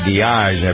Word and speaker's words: Diage: And one of the people responsible for moving the Beatles along to success, lady named Mickey Diage, I believe is how you pronounce her name, D-I-A-G-E.